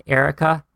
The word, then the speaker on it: Erika: So if you guys want to reach out, my Instagram handle is my name, Erica, E-R-I-K-A. That's spelled the German way. Erica Barker, B-A-R-K-E-R.